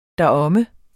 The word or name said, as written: deromme